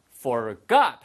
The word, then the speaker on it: forgot: 발음 주의하세요. 예, forgot이 아니고요. forgot 하고 뒤에 액센트가 있습니다.